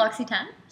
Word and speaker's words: L'Occitane: Yeah, L'Occitane. – Yeah. – Yeah, so L'Occitane, they're a skincare and beauty product company. So we just launched a new program with them. So you can go to specific L'Occitane stores and bring in your old L'Occitane products, and they're going to be recycled with us.